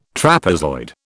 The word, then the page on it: trapezoid: British English